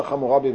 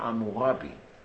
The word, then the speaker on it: חמורבי: כי שמו בעצם אמורבי, רק שהאקדים היו מבטאים את העין הזאת קרוב לחטא, אבל זה אמורבי, אמירב בעברית שלנו, משפחתי הגדולה, עמי החזק, וכמעט עברית.